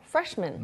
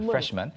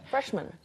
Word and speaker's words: freshman: 그런데 이게 한 단어가 되면서 그렇게 freshman이라고 하진 않아요. – No, freshman. – man처럼 발음을 합니다. freshman. – freshman.